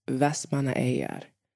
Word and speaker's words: Vestmannaeyjar: And that is Vestmannaeyjar. So I will say it slower. Vestmannaeyjar.